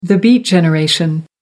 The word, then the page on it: beat generation: American English